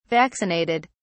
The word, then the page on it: vaccinated: British English